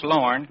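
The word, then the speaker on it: Loraine: They'd ask me what my name was, and I'd say Lauren Ray. They say, how do you spell it? L-R-A-I-N-E? Lorraine? No, I said it's pronounced Lauren.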